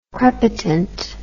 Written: crepitant

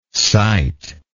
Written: citeable

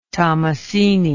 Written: tommasini

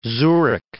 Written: Zurich

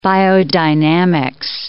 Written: biodynamics

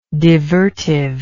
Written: divertive

